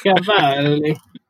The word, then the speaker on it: Cavalli: The Nats went and got Cade Cavalli with their first round pick. Am I saying that correctly? Out of Oklahoma. – Yep. – Mm-hmm. You got to say like a American Italian Cavalli. – Cavalli.